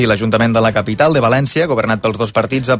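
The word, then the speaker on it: Valéncia: La versió en català s'haurà d'escriure amb accent tancat a la E i s'haurà de pronunciar, per tant, València.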